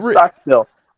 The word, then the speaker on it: Stockstill: First of all, Curtis, you've got to say his name right. – No, I don't have to. I got it. – Stockstill. Brent Stockstill. – Still? Like a still with still. – Like a still you make alcohol in? – Yes, Stockstill.